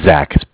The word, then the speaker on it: Zach: Zach, Z-A-C-H.